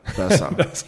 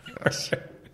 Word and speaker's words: BESA: Is it BESA or BESA? – First time you're right. BESA. BESA.